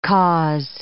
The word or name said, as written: cause